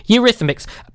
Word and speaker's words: Eurythmics: Eurythmics. – I think you're bang on the money. That is it. Eurythmics. Eurythmics. Eurythmics.